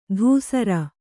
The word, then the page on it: ದುಸ್ಸರ: Kannada